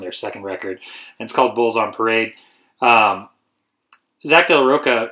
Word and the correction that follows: Rocha: am I not saying that right? – Rocha? I think it's Rocha.